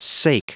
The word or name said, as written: sake